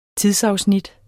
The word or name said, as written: TIDSAFSNIT